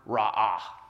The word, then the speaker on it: ra'ah: It's a Hebrew word that appears 9 times in this short book, and it's the word ra'ah. Can we say that together? Ra'ah. Yeah, if when you go to that second syllable, it sounds like you're coughing something up. You're saying it right, all right?